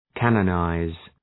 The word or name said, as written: canonize